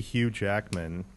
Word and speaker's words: Hugh Jackman: But, maybe Hugh Jackman.